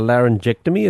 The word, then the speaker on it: laryngectomy: So a laryngectomy, is that how you pronounce it? – That's correct, yes.